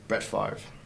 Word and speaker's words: Brett Favre: Brett Favre.